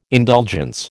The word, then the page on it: indulgence: American English